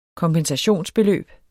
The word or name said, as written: KOMPENSATION